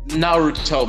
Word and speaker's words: Naruto: Naruto? How you pronounce that? – Naruto, man. Get it straight.